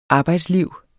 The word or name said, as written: arbejdsliv